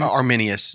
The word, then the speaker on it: Arminius: Arminius.